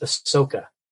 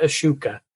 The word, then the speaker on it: Ahsoka: Her name is Ahsoka, not Ashuka, not Ashoka. – Ashoka. – Ahsoka. – Ashoka. – Ahsoka. – Ahsoka. – Like a squirt Soka. – Ahsoka.